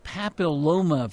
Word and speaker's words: papilloma: This is the human papilloma virus, HPV. Am I pronouncing that correctly? – Absolutely right.